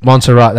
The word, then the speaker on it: Montserrat: Oh, Montserrat.